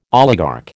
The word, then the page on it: oligarch: American English